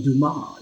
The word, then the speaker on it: Dumas: The S is silent. Dumas. It's French, baby.